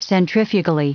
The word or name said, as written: centrifugally